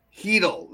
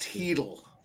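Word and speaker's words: Hertl: Nas fan called it the Panarin and Chittle line. – Heedle. That's pronounced Heedle.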